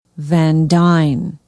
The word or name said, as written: van dyne